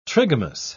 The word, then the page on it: trigamous: British English